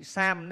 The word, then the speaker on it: tham: Kim ngôn tham giả, chúng ta cứ đọc là chữ sam đấy, nhưng mà quen hay đọc là tham thiền, tham. Nay